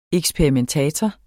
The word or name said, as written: eksperimentator